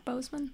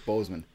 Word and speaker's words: Boseman: Chadwick Boseman. – Pardon? – Chadwick Boseman. – Chadwick Boseman.